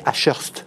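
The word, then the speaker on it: Ashurst: cabinet à Schurst. Absolument. bien prononcé au Vous avez parfaitement prononcé.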